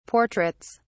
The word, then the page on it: portraits: British English